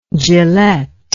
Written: Gillett

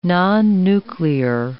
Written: nonnuclear

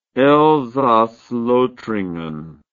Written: Elsass-Lothringen

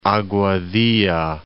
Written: Aguadilla

